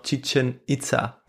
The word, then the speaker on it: Chichen Itza: Ich nehme noch Chichen Itza. – Sehr schön. – Das richtig ausgesprochen? – Das hast du ziemlich gut ausgesprochen.